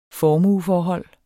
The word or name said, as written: formueforhold